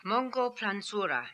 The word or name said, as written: Mongolplanzura